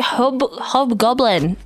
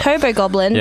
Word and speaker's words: Hobgoblin: CLC, Hobgoblin. Not Hobo Goblin.